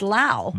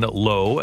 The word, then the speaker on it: Lowe: So how do you get what I would perceive to be Low to be pronounced Low? – The Cardinals had a pitcher back in the day named Sean Low, L-O-W-E.